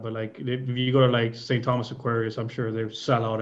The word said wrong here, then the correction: Aquinas: But like, you say St. Thomas Aquarius or Aquinas? – Aquinas, probably.